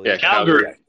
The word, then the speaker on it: Calgary: Calgary.